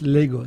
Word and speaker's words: Lagos: Lagos, ça s'écrit Lagos, comme au Portugal, L-A-G-O-S, mais ça se prononce Lagos. – Lagos, à l'anglaise en fait.